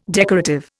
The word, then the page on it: decorative: Indian